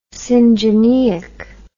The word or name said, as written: syngeneic